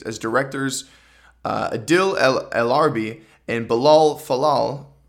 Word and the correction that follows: Fallah: Fallah, pardon my pronunciation,